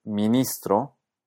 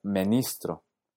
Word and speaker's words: ministro: Por ejemplo, se podía pronunciar ministro o menistro, vanidad o vanedad, civil o sevil, teatro o teatro.